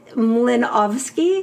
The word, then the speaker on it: Mlynowski: I mean, I guess it's Polish-Jewish, so it's pronounced... It's pronounced Mlynowski because it's a Polish double.